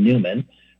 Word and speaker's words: Neumann: from St. John Neumann, or sometimes pronounced St. John Newman, right, or even Catherine Drexel.